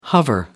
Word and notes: hover: American English

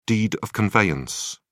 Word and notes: deed of conveyance: British English

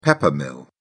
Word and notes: pepper mill: British English

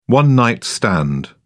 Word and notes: one night stand: British English

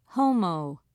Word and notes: homo: British English

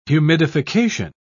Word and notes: humidification: British English

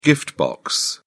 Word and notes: gift box: British English

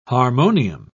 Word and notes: harmonium: British English

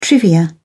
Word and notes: trivia: British English

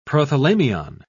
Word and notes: prothalamion: British English

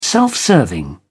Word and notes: self-serving: British English

